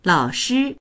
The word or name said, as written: lao3shi1